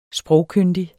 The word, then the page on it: sprogkyndig: [ -ˌkønˀdi ]; [ -ˌkøndi ]